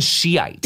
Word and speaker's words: Shiite: So it's spelled S-H-I-I-T. – Yes. – Some people put the E, some people don't. – Okay, I think I said Shiite.